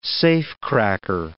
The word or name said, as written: safecracker